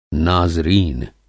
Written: नाज़रीन

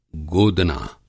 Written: गोदना

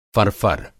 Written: फर-फर

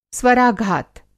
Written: स्वराघात

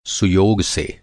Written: सुयोग

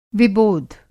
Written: विबोध

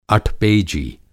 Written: अठपेजी

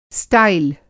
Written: स्टाइल

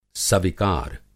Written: सविकार

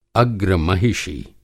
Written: अग्रमहिषी

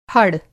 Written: फड़

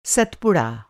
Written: सतपुड़ा

